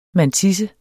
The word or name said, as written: mantisse